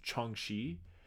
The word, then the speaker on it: Jiangshi: It's spelled J-I-A-N-G-S-H-I, but I did look this up how to pronounce it. It's pronounced Changshi.